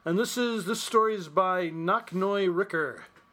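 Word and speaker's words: Nok-Noi: N-O-K dash. This motherfucker's got a dash in his name. N-O-K dash N-O-I. R-I-C-K-E-R. – That's right, it's Knocknoy, I would pronounce it. Knock Noy Ricker.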